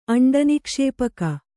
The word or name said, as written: ಅಂಡನಿಕ್ಷೇಪಕ